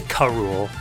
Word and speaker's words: Kahrule: They pronounce it Kahrule, which is fine.